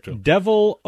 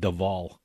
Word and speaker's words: Devol: So this is Devil, Oklahoma. D E V O L. – Yep. – Okay. See, I instantly would have said Deval.